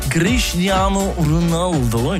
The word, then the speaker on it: Cristiano Ronaldo: Cristiano Ronaldo, ich glaube, so spricht man es richtig aus auf Portugiesisch, hat ja gerade geheiratet, seine Georgina Rodriguez.